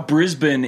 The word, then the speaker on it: Brisbane: Yeah, I would say, is Brisbane the American saying of it? – No, Brisbane is the correct way to say it. – Brisbane is. – Brisbane is the incorrect way.